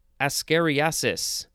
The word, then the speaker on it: ascariasis: Now we have ascariasis. A-S-C-A-R-I-A-S-I-S. The emphasis is on the re, right in the middle.